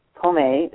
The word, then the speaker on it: pomme: P-O-M, as in Marie, E. Pomme is how some people pronounce it, fruit.